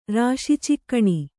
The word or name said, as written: ರಾಶಿಚಿಕ್ಕಣಿ